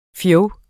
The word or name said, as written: fjog